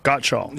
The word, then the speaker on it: Gottschall: Did I say it right? Gottschall? Gottschall. – You got it.